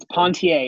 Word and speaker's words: Pontier: So yes, it's Pontier. It's French.